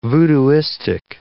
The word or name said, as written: voodooistic